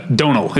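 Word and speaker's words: Domhnall: Donal.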